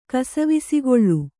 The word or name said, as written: ಕಸಿವಿಸಿಗೊಳ್ಳು